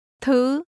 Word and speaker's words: thứ: Tôi mua nhiều thứ lắm. Thứ. Thứ. – Things is pronounced with a high rising tone. Say it again.